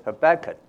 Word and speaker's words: Habakkuk: Our prophet today is Habakkuk. – 我们今天的这个先知呢，讲的是哈巴谷。 – Some people pronounce it Habakkuk. It's the same.